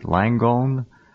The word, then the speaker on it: Langone: This is Dr. Amy Rapkowitz, chairman of the Department of Pathology at NYU's Langone, is that how you pronounce it? Langone Medical Center.